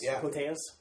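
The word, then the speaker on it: Koteas: It has Elias Cotes, if I'm pronouncing it correctly. – Cotes.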